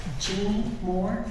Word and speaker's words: Jeannie Moore: Jeannie Moore, J-E-A-N-N-I-E-M-O-O-R-E.